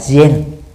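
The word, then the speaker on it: gen (禅): Nhật Bản gọi chữ thiền trong Hán Việt đó theo cách phát âm của họ là gen tức là viết cùng một chữ thôi khác nhau về phát âm để thực tập uh,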